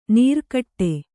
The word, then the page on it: ನೀರ್ಕಟ್ಟೆ: nīrkaṭṭe; kannada